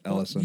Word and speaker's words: Elysian: Elysian Brewing. – They're in Ellison. – Ellison Brewing. – You're killing me. It's Ellison.